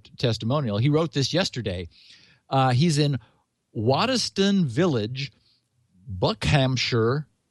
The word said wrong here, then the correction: Buckinghamshire: or is it Buckinghamshire. – Buckinghamshire.